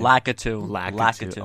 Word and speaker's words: Lakitu: Every time I see a Lakitu, is that how you pronounce his name? – Lakitu.